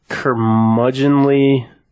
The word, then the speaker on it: curmudgeonly: I don't even know how to say this word. A curmudgeonly... C-U-R-M-U-D-G... – Curmudgeonly, yeah. – Yeah, you got it.